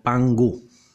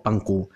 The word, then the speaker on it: Pangu: Este creador, según la filosofía china, se llamaba o se llama, para los que creen en su existencia real y actual, Pan o Pangu con G de gato, pan como el pan que comemos en la mesa, y Gu con G de gato, g o que se escribe KU.